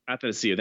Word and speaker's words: Athanasiou: Uh, so like if you put him, I mean, imagine him did in his his teammate, and I'll say the name wrong, but Andreas Athanasiou. Uh, I think. – Athanasiou, thank you.